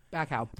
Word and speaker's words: Bacow: Bacow.